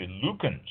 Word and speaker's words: Luekens: And I got those, I left, went down the street to Lukens. I think that's how it's pronounced, L-U-E-K-E-N-S, Lukens.